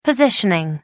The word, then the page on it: positioning: American English